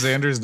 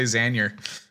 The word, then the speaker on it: Xander: Did you just mispronounce Xander's name? – What? Did I? – I thought I heard you say Xander.